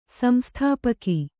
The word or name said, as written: ಸಂಸ್ಥಾಪಕ